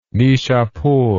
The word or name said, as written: nishapur